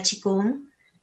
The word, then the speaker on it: Qigong: Qigong médico, que ustedes van a decir qué tiene esto, es Qigong, ustedes lo van a ver escrito como Qigong o Qigong, sí se pronuncia Qigong.